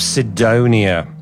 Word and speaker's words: Sidonia: That was Muse with Knights of Sidonia. That's how you say it, Sidonia, folks.